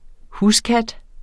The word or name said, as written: huskat